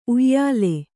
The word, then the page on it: ಉಯಿಲು: Kannada